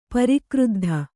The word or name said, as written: ಪರಿಕ್ರುದ್ಧ